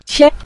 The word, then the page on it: qie: qie5